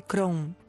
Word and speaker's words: Crohn: Se llama enfermedad de Crohn. Se escribe C-R-O-H-N.